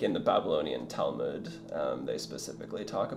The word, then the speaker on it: Talmud: Talmud, yeah.